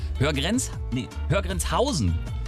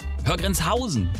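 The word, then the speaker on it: Höhr-Grenzhausen: Häufig liegt die Betonung auf Höher. Und der Bindestrich wird deutlich gemacht. Grüße an Wirbi. Hey, wir nennen keine Namen hier in dieser Sendung. Richtig ist die Betonung auf der dritten Silbe aber. Hörgrenzhausen. Hörgrenzhausen. So muss es wohl richtig ausgesprochen werden.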